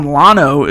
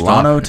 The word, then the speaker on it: Llano: The Texas town Llano is pronounced with the L sound, and they get very upset when you use the Y sound. Llano, Texas.